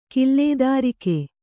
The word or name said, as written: ಕಿಲ್ಲೇದಾರಿಕೆ